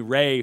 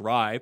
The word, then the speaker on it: Rai: Jimmy Ray, who wants to know, but actually pronounced Rye, but worked better if his last name was Ray. R-A-I. Interesting.